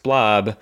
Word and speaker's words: Blab: Uwe spelled U W E last name Blob, B L A B, but pronounced Blob.